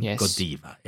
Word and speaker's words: Godiva: But, you know, obviously, if we want to stick to the Belgian roots, then Godiva it is.